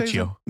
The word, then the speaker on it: Michio: Mikio. Mikiko? How's he Michio.